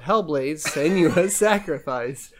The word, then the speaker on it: Hellblade: Senua's Sacrifice: Hellblade: Senua's Sacrifice.